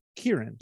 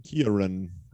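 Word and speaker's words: Ciarán: I think it's Kieran. – Kieran. – Kieran Hines. I don't know. It's in the pronunciation, it's K-E-E-U-H, then R-U-H-N. So.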